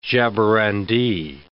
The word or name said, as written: jaborandi